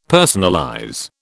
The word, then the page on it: personalize: British English